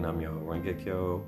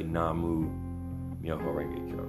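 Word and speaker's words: Nam-myoho-renge-kyo: Or some people say Namu Myoho Renge-kyo.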